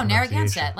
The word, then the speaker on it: Narragansett: However, Todd did reply saying, I either do this or I go jump off the Narragansett Bridge. I can't live with myself any longer, end quote. I'm sorry if I'm butchering that. – No, Narragansett, like the beer. Narragansett, yeah.